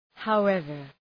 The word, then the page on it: however: haʋ’evər